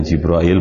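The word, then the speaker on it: Jibril (جبريل): Kemudian wa Jibril atau Jibril, dibaca Jibril bacanya, sami'ahu minhu, mendengar dari Allah wahyu tadi.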